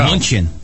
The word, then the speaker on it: München: München.